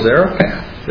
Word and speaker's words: Zarephath: But it also parallels in this position with the woman, the widow Zarephath. Am I saying it right? – Yep.